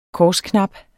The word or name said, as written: korsknap